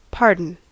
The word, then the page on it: anteeksi: [ˈɑnteːksi]; /'ɑn.teːks/; ['ɑn̪eːksi]